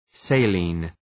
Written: saline

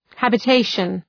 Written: habitation

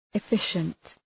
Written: efficient

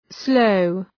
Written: sloe

